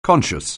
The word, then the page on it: conscious: anglais